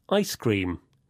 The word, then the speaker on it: ice cream: With compound nouns, the stress usually goes on the first part, like this. Popcorn, ice cream, keyboard.